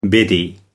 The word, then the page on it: biddy: American English